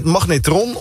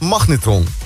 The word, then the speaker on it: magnetron: Je hoort toch wel waar de klemtoon ligt? De klemtoon. – Het is meer magneet, dus ik doe even heel extreem. – Nee, niet op die middelste. Het is magnetron of het is magnetron.